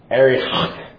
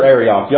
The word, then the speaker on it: Arioch: Now, the next person, Arioch, if I can pronounce his name in Hebrew like the experts can, it's like Arioch. It's something like that. It's like you're clearing your throat. Arioch, king of Ellasar.